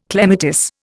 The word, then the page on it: clematis: Indian